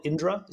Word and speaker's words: Indra: Is it called Indra? Is that how I pronounce it? – Yep, that's right.